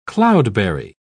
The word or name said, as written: cloudberry